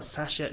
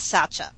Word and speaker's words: Satya: tell us about Satya or Sasha Nadella. I believe there's two pronunciations on this in the air. Which one are we going with on this? – Let's go with Satya. Just because that's what I'm so used to saying.